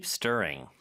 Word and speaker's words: stirring: Stirring. 자, 요 발음할 때 R 발음. 혀가 어디에도 닿으면 안 됩니다. 하지만 양쪽을 긴장시켜줘야 되겠죠? 그리고 ST의 T는 쌍디귿으로 발음하면 좀 더 편해요.